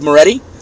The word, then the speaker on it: Moretti: My profile is Chaz Moretti. It's my name. – That's Chazz? – Correct. Chazz. The last name is spelled M as Mary, O-R-E-T-T-I. Chaz Moretti.